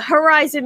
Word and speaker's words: Herizon: Herizon Music with the H-E-R because we are female-centric.